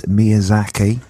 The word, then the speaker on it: Miyazaki: I saw the last, is his name, hopefully I don't butcher this, Miyazaki? – Yeah.